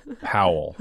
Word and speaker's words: Powell: I like this pronunciation of Powell's name. – Okay, it's Powell. – Rhymes with President rhymes with towel? Powell.